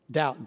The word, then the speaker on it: doubt: Doubt, D-O-U-B-T. Where do you get a B from? D-O-U-B-T, doubt? I would spell it D-O-W-T, doubt. The reason is D-O-U-B-T is because it comes from another word, which is double.